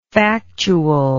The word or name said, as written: factualness